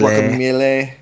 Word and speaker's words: Guacamelee: Playing Super Time Force for the first time and Guacamelee. – Guacamelee, yeah. – Gotta say it right. People get very upset when I mispronounce Melee.